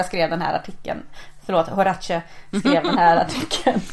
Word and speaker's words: Horace: Horace, säger jag då. – Jag tänkte säga Horas. – Så att Horace.